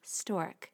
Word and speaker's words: Storq: And this sponsor is Stork, and it's spelled S-T-O-R-Q.